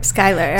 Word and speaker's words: Schuyler: Skyler. – Skyler. I feel like it's so. It's spelled S C H U Y L E R. Skyler. Schuyler.